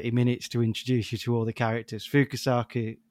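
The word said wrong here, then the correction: Fukasaku: sorry if I'm butchering his name. Is it Fukusaki? – Fukusaku.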